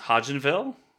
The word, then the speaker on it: Hodgenville: It's Hodgenville? – Yeah. – H-O-D-G-E-N-V-I-L-L-E? – Correct.